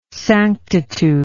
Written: sanctitude

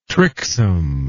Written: tricksome

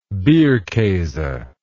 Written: bierkase

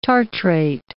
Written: tartrate

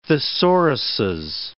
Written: thesauruses

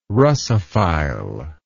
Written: Russophile

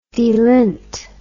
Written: delinter

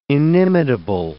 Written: inimitable

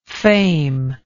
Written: fameless

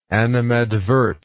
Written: animadverter

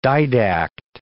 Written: didact

